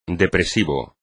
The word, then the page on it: depresivo: espagnol